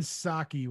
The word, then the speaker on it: Psaki: It's Jen Psaki with a silent P at the beginning.